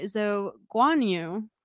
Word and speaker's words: Zhou Guanyu: And it's inspired by Zhou Guanyu.